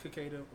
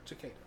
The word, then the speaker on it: cicada: And like, this is the first time I've heard it pronounced cicada. – How do you pronounce it? – I've always heard it either pronounced cicada or cicada, which is weird.